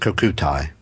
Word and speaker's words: Kokutai: What was the nickname for the famous Elite 343 Kokuti Squadron of the Imperial Japanese Navy? – I don't know, Mike. – Actually, I think it's pronounced Kokutai.